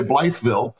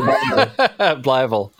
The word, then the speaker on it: Blytheville: So, you know, and we say it right. We don't say Blytheville. – No. – We say Blytheville.